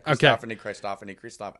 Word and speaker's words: Christophany: So the angel of the Lord shows up, and you said that this was a Christophany. Am I saying that correctly? – Maybe. You're saying this best tonight. Christophany. I'm just saying it fast.